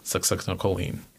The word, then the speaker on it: Succinylcholine: Succinylcholine. Yeah. – Can you spell it for me? – It's Succinylcholine. O-L-I-N-E.